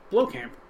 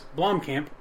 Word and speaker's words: Blomkamp: Blomkamp. There's an M in there. – There is. Blomkamp.